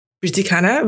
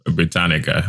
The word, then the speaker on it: Britannica: And according to uh, Brit- Brit- Britannia, Brit- Britannia, I'm sorry, I don't know how to pronounce this word very well. Uh, uh, apologies. – Britannica.